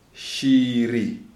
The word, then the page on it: Shiri: Shona